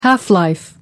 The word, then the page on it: half-life: American English